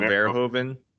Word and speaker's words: Verhoeven: Paul Verhoeven.